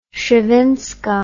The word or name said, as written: szewinska